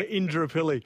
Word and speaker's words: Indooroopilly: And so Indooroopilly. Is that how I'm saying it right? – Yeah, Indooroopilly.